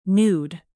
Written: nude

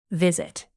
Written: visit

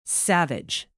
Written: savage

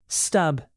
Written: stub

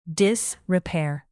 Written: disrepair

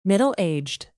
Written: middle-aged

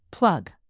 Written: plug

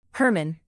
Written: Herman

